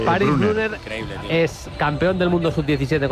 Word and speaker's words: Brunner: Paris Brunner, con 2 N's, Brunner. – Brunner Alemania. – Ahí no tiene problema el Tala en pronunciar, Bruno. – Brunner.